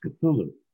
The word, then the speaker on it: Cthulhu: I mentioned Lovecraft, and I mentioned Cthulhu, but I didn't say Cthulhu. I forget exactly how I pronounced it. And he corrected me and he said, no, it's pronounced Cthulhu.